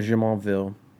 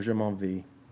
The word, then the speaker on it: Jumonville: But the big thing that happens is that the Half King comes up to Lieutenant Jumonville, or Jumonville, English pronunciation, French pronunciation, whichever.